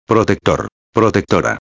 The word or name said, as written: protector-a